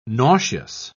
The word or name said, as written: nauseous